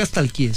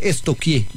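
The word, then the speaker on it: stalkear: Porque no se dice nunca estalquié, se dice estoquié. – ¿Estoquié? Sí. – Pregúntele a la profe, verá. A mí una vez estamos estoqueando. – Sí, estoqueando. Yo, pero se escribe estalquear. Es estoquear.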